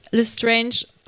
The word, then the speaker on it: Lestrange: Also Rufus Beck spricht Lestrange auch französisch aus wie Stefan.